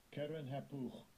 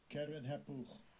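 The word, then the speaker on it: Keren-happuch: Keren Hapuch. Keren Hapuch.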